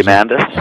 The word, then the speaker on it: Diamandis: Diamandis.